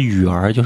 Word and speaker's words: 女儿: 湖南发音就是女儿的意思啊，就是雨儿，就是女儿的意思。